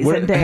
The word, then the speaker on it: Zendaya: You guys said it right. Zendaya. – How do people mispronounce it? – A lot of people say Zendaya.